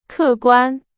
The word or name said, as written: 客观